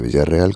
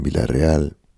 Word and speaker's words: Villarreal: Y claro, pedaleamos entre naranjos, llegamos al Mediterráneo, estuvimos en Villarreal. Nos acompañó en esta mitad de, en este comienzo de trayecto, una periodista oriunda de Villarreal. Me explicó que no se dice Villarreal, el pueblo, sino que es Villarreal.